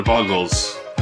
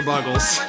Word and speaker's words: Buggles: Just the way he said it is like he's not saying the Buggles, you know? – No, it's fine. That's how you're supposed to pronounce it, right? Like, you're just supposed to be like, The Buggles. – Oh, it's The Buggles.